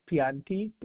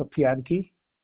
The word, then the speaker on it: Pianti: First is Pianti.